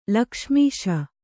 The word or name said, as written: ಲಕ್ಷ್ಮೀಶ